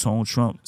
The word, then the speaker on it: Tone Trump: It's just Tone Trump, sound like it's spelled.